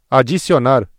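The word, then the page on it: adicionar: Brazilian Portuguese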